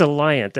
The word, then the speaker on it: Alliant: Allegiant. – Or is it Alliant? It's Alliant, actually. Alliant Energy Center in Madison, Wisconsin.